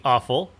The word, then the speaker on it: offal: The lesser meat was called the offal, and that's spelled O-F-F-A-L. It's pronounced like awful.